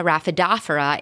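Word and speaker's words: Rhaphidophora: And he also has one of the largest private collections of Monstera, Syngonium, Syndapsis, and Raphidophora, pronounce it correctly, Maria, Raphidophora, in the country.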